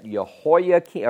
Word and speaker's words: Jehoiakim: He reigns for 3 months, was dethroned by Necho, and replaced with a son of Josiah named Eliakim And we call that, we pronounce that Jehoiakim, or Jehoiakim in English.